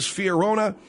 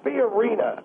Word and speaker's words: Fiorina: I thought it was Fiorina. You're telling me... – It's Fiorina. Fiorina. Is that right, Dr. Sadler? – Yes, Carly Fiorina.